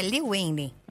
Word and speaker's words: Lil Wayne: O rapper Lil Wayne, completando 41 aninhos. Falei certo o nome dele, não, né? – É, Lil Wayne.